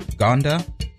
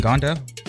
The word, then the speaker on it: Ngonda: I think it's Gonda. Jalen Gonda. Spelled N-G-O-N-D-A. I believe the N is silent.